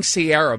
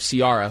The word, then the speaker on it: Ciara: She kept saying Sierra, by the way, instead of Ciara, who was performing at halftime, which, by the way...